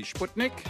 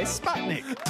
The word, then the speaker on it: Sputnik: The Russian pronunciation, which is where it comes from, would be Sputnik. But Mike, you're also correct because I say Sputnik.